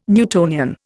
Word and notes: Newtonian: Indian

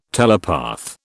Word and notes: telepath: British English

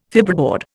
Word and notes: fibreboard: Indian

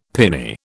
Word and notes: piny: British English

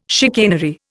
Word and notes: chicanery: Indian

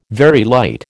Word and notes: Very light: American English

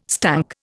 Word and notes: stunk: Indian

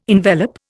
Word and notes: envelop: Indian

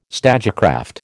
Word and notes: stagecraft: American English

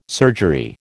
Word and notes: surgery: American English

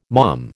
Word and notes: mom: American English

